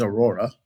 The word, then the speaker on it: Aurora: So you guys are looking at sites, their performance, the team you work on, Google's Aurora team. Am I saying that right? – Yeah, no, you're saying it perfectly right. Yeah, our specific team is Aurora, and we're part of Chrome.